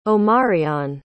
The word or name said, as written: Omarion